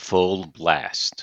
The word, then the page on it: full blast: idiom